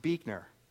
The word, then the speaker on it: Buechner: It is pronounced Buechner.